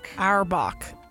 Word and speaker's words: Auerbach: Auerbach.